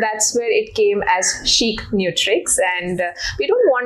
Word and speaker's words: ChicNutrix: Coming to the name, Chic Nutrix, yeah, as we pronounce it. And I know there are different pronunciations that we hear every time, but it was the word chic, if you know, it means stylish. It means something we say, it's chic.